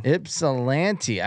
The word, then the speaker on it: Ypsilanti: Ypsilanti.